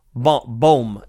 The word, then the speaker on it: Bohm: Because when you look at an Alec Bohm, and I always say his name wrong. Let me make sure. Bohm.